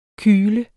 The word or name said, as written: kyle